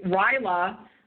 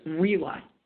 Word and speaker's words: RILA: So it's RILA. And for anybody who's heard Brand Elverston say RILA for 30 years, that is a mispronunciation. It's RILA.